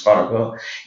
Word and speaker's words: Farago: We come back, and in conversation, coming back to his door the second time, I tell him my last name is Farago. You know, I know that it to be, you know, the original pronunciation is Farago.